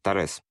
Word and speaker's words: Торез: И тут указано, что они доставляли к этой шахте людей из города Чистяково, ныне Торез. Правильно я ударение? Торез? – Да, в вот.